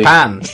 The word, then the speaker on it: Sapan: Last year, AMC Network CEO Josh Sapan made headlines when he said, "We'll be sitting here in 2022 discussing the fact that Walking Dead is not over." – I think it's pronounced really, Sapan.